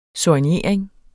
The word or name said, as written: soignering